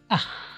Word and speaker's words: ach: Two sounds that can be a little difficult for English speakers to pronounce are ch, as in ich, and ch, as in ach. The ach sound is pronounced at the back of the throat, like the ch sound in the Scottish word loch.